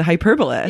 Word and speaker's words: Hyperbolist: I'm your host and I'm here today with the one and only the Hyperbolist. – Yes. You said it right. Some people are like, the hyperbolist.